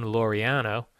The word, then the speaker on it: Laureano: The Astros hit the A's Ramon Laureano.